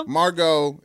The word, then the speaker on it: Margot: Oh, it got a T at the end. – Margot Rubio. – Margot. It's a T, silent T. Margot Robbie. Margot. Is that her name? – Yes.